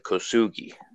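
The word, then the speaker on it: Kosugi: But so the cast show Kusugi. I'll say it properly, Kusugi.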